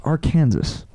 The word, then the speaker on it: Arkansas: Dean, it's Arkansas.